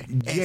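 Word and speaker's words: Jaecoo: Jayco, así Jayco. Se pronuncia. Jayco, la pronuncia mal. – Jayco. Es correcto. – Okay. – Es un error común, tranquilo.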